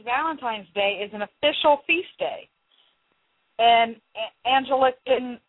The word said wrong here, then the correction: Anglican: I know I'm saying that wrong. Anglican Communion, sorry, as well as the Lutheran Church.